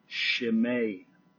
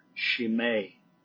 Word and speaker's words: Chimay: And in the 1860s, 70s therein, we know that Trappist monks started brewing a beer called, it's actually pronounced Chimay beer. Chimay. We probably look at it Chimay and call it Chimay beer.